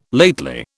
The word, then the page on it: lately: British English